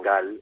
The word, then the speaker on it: Galle: y luego el otro hotel está en Gal, o Galle, como se escribe,